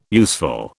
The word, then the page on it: useful: British English